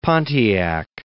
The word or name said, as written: Pontiac